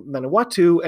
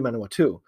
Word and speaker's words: Manawatu: I had been saying Manawatu, and it's really Manawatu.